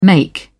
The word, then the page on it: make: British English